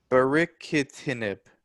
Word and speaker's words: Baricitinib: Baricitinib.